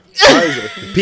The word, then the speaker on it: Pfizer: Pfizer. – P is silent, brother.